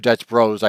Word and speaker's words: Dutch Bros: So for instance, various parts of the country, we have Dutch Brothers, or Dutch Bros, I should say, if you're younger, I guess that's how you say it.